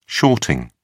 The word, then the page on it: shorting: British English